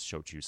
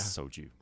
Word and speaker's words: shochu: And so I have a bit of experience shochu sales myself. And one problem I would have in London is that a lot of people would mispronounce shochu as yeah. soju.